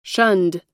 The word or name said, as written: shunned